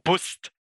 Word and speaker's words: BUSD: Und zwar der nächste größte Stablecoin nach Marktkapitalisierung ist dann BUSD oder BUSD. Oder Bust. Oder Bust, genau, wenn man das ganz schön deutsch aussprechen möchte.